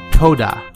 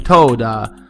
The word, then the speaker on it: Toda: D-A-N-K-E. Danka. Question 2. Toda. T-O-D-A. Toda. If I do mispronounce it, I apologize. Question 3. Merci. M-E-R-C-I.